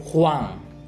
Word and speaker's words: Huang: And my name in Mandarin is Huang. It means yellow. That's why if a Nang becomes an immigrant, transfers to United States, the last name is converted to Huang, H-U-A-N-G.